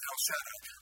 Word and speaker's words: Shaddai: By the way, it's not the way it's pronounced. Shaddai does not sound good in a song, so they softened it a little bit. But that's how it's pronounced, El Shaddai.